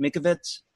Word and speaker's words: Mikovits: Mikovits.